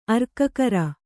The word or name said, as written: ಅರ್ಕಕರ